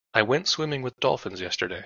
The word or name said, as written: dolphins